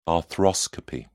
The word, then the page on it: arthroscopy: British English